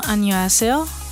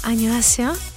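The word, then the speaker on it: Ανιωάσεο (안녕하세요): Ανιωάσεο. – Πώς. – Ανιωάσεο. – Ανιωάσεο. – Σωστό. – Έχεις και την προφορά, έτσι, καταλαβαίνεις. – Πρέπει η φωνούλα να πηγαίνει λίγο πιο πάνω.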